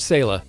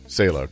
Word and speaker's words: Saleh: Robert Saleh. – Saleh, okay.